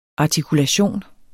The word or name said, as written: artikulation